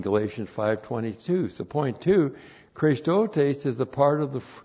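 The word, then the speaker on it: Christotes (χρηστότης): Once again, n plus the dative of means, by means of Christotes. C-H-R. Long E, S, T, O, T, long E, S, Christotes.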